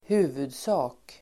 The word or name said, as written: huvudsak